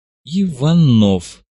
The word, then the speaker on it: Иванов: Иванов. Само собой, с ударением на втором слоге.